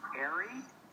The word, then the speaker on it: Airey: Don Airey, and that is spelled A-I-R-E-Y.